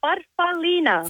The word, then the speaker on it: farfallina: Farfallina.